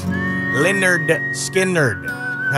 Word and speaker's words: Lynyrd Skynyrd: Pronounced Lynyrd Skynyrd.